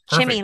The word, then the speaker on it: Chemi: Chemi, like chimichangas.